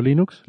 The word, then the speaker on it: Linux: Había un audio que decía, decía algo así como, hola, soy Linus Torvald y yo pronuncio Linux, Linux.